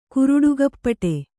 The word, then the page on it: ಕುರುಡುಗಪ್ಪಟೆ: kuruḍugappaṭe